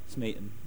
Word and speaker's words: Smeaton: Yeah, it rhymes with beaten, actually. It doesn't rhyme with policeman. Friend of a friend got beaten, blah, blah, blah, blah, blah, blah, blah, blah, blah. Smeaton.